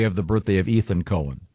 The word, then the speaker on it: Cohen: No, not when it's spelled C-O-H-E-N. – Yes. It's definitely Cohen.